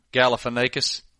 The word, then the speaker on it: Galifianakis: We're going Zach Galifianakis. – No, say his name. I want to hear you say it. – Galifianakis.